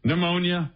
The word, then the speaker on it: pneumonia: Pneumonia. Of course, that's always been a classic. Right. The silent P and the E-U in there.